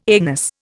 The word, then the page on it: anus: Indian